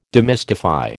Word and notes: demystify: American English

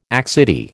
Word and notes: accidie: American English

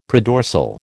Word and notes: predorsal: American English

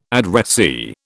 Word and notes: addressee: British English

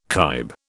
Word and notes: kibe: British English

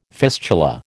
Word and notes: fistula: American English

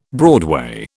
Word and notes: broadway: British English

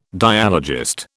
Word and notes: dialogist: British English

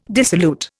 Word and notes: dissolute: Indian

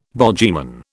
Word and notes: bogyman: British English

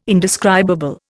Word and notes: indescribable: Indian

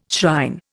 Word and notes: shrine: Indian

